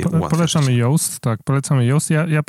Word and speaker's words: Yoast: która się nazywa Yoast pisze się y OAST, y Yoast SEO, mm, która ułatwia prowadzenie y, własne, własnoręczne prowadzenie pozycjonowania strony www.